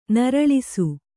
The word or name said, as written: ನರಳಿಸು